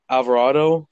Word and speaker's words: Alvarado: Alvarado from Tampa Bay.